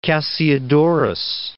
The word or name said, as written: Cassiodorus